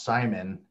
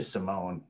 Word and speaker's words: Simone: my mom always told me my last name is pronounced Simon. And then one day she told me like, oh, by the way, it's actually Simone because it's like Hispanic.